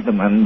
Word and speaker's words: Hesemann: Both ways, whatever you prefer. The German way is Hesseman, but it is rather difficult to pronounce, so whatever you prefer.